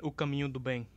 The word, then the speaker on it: O Caminho do Bem: O oh Camino de Bem. Jesus Christ, I butchered the fuck out of that. Whatever that yeah, one is. Um, um, it's Que Beleza and O Caminho do Bem.